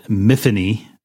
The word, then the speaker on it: Miffany: So the pilot has this character called Miffany, rhymes with Tiffany.